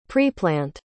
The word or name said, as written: preplant